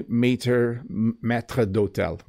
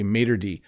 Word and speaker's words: maitre d': So we don't say maître d'hôtel. We don't say that in English. We just say maitre d'. And I apologize to all my French viewers. That's how we pronounce it. We say maitre d'.